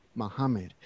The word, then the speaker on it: Mohammed: It's taken me almost 2 years to pronounce Mohammed the correct way, Mohammed, right, in Arabic.